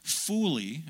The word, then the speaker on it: folie: Well, if you look in the dictionary of etymology, the origin of words, this comes from the old French word, folie. It's spelled F-O-L-I-E, folie, and it means folly, madness, and stupidity.